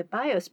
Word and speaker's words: BIOS: So, BIOS is that acronym you see all the time, B-I-O-S.